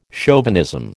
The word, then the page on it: chauvinism: American English